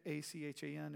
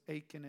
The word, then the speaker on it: Achan: You have uh, Achan, A C H A N. Is that right? How you would pronounce it? Anybody? Achan.